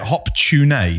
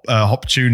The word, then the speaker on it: Hop-tu-Naa: They call it Hop Tune. – Right. – But this is... I was reading an article about Hop Tune, but it's spelled H-O-P... -TU-NAA. – Okay. – So it sounds like hot tuna.